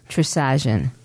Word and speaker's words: Trisagion: Trisagion.